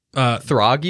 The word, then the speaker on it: Throg: What episode do you see Froar? – Throg, you mean? – Yeah, sorry.